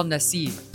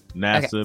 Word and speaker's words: Nassib: Let me ask you about another sports story, and that's Carl Nassib, right? Is that how you pronounce his last name? – Yeah. Nassib, okay.